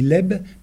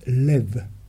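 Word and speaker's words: Lev (לב): Vous voyez que ça s'écrit Leb, mais ça se prononce Lev.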